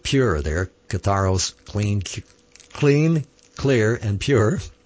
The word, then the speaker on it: katharos: And Yahweh is going to clean us and make us pure, to be separated in Kodesh, but in Greek, Greek word 25:13, katharos, or katharos, K-A-T-H-A-R-O-S, phonetically there, katharos, means to be clean, clear, and pure.